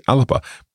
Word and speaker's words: Alba: For example, the BBC Scotland in Gaelic has written BBC alapa, but it's written slightly differently from how it's pronounced. – It is. As with many words in Gaelic, it's not the spelling you would necessarily expect. So alapa is written A-L-B-A. So in English, we often say alba.